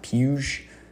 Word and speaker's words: Puig: It's spelled Puig, like Yasiel Puig, but I've seen this on the broadcast, and I'm pretty sure it's pronounced Puge. I think I did that right, David Puge.